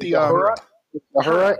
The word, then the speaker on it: Uhura: Uhura, is that how you say it? – Uhura and Solo.